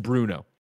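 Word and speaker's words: Brno: Only one today from C Order 1988, who says, Greg, the city in the Czech Republic is pronounced Bruno.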